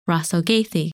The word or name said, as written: Rasalgethi